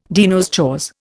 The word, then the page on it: de nos jours: Indian